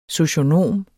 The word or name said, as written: socionom